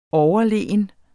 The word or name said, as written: overlegen